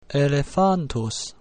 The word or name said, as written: elephantus